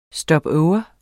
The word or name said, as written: stopover